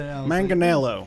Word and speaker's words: Manganiello: I looked up a way to pronounce it. – Manganiello. – Like, it's Italian. – Manganiello, okay. – It's definitely Italian.